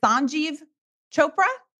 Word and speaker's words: Sanjeev Chopra: Is it Sanjeev Chopra? – You got it.